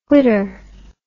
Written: whitter

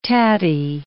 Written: tatty

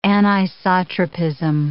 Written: anisotropism

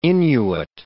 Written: Inuit

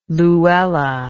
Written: louella

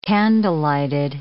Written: candlelighted

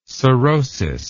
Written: sorosis

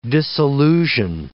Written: disillusion

